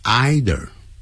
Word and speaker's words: either: And you can't buy true friendship either，你也不能买到真正的友谊，逗点加 either，也有老外念成 either，这个叫也，跟那个 to 用法一样 T-O-O，但 to 是用在肯定句。